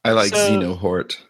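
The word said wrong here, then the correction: Xehanort: So well, Xenohort, if we're going to call him that, even though that's how will you actually pronounce it? Is that it was Xenohort? – Zahanort. – It's Zeanort. – Zeanort. – Yeah. – Oh, I put the emphasis on the wrong syllable.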